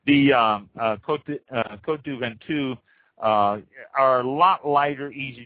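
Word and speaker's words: Côtes du Ventoux: Next one, the Côte du Ventoux. That's spelled Côtesdu Ventoux. That's Côte du Ventoux.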